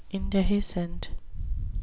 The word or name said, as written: indehiscent